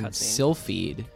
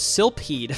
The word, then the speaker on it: Silpheed: I like that name, Silphied. – Yeah, it's spelled really confusingly. It's spelled S-I-L-P-H-E-E-D. So I thought it was Silphied for a while.